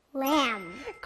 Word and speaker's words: lamb: 친구들 여기 끝에 ㄂ 소리는 안 나요. 그래서 오늘 배운 첫 번째 단어 ㄂ 하고 오는 아기 양 영어로 뭐였죠? – Lamb.